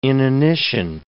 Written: inanition

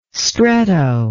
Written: stretto

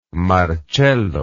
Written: marcello

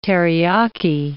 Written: teriyaki

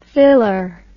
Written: thiller

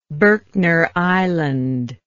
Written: berkner island

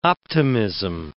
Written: optimism

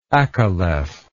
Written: acaleph

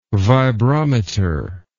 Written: vibrometer

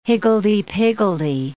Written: higgledy-piggledy

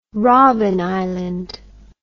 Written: robben island

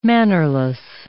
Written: mannerless

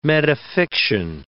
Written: metafiction

